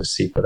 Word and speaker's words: Vascepa: The drug in question, as you mentioned, is Vascepa. I think it's called Vascepa. That's how the advertisements pronounce it.